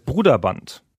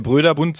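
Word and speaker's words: Broderbund: Es heißt Gut. Bruderband. – Bruderband. – Mit langem Total U. Total anders, als man es intuitiv sprechen würde. – Genau, intuitiv würde man ja Brüderbund sagen oder so.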